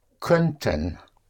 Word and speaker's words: könnten: So we have können for can, and then we have könnten. Könnten is spelled K-O-Umlaut, double N-T-E-N, -E könnten. Könnte actually means could.